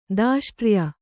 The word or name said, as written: ದಾಷ್ಟ್ರ್ಯ